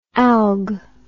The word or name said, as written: alg